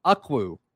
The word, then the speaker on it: Ukwu: My number four on this list, as far as guys that we wish Auburn could just go get from the portal, Isaac Ukwu. I think that's how you pronounce it. His last name is spelled U-K-W-U, but he is an edge.